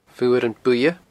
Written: Fuaran Buidhe

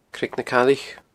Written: Creag na Caillich

